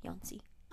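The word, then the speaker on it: Jónsi: It's Jonsi, but the J is pronounced like Y, Yonsi.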